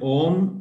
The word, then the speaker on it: Aum: So Om, O-M, actually pronounced Om, A-U-M, Om, Om. So when you pronounce it, it's pronounced Aum, A-U-M, if you write it down.